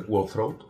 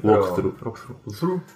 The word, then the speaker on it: walkthrough: Pones en Google World Throat. Ya está. No, sé si lo he pronunciado bien. – Walkthrough.